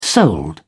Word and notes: sold: British English

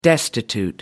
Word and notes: destitute: American English

